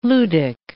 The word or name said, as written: ludic